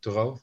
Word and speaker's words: Thoreau: Thoreau, is that how you pronounce it? The American poet. – Yeah.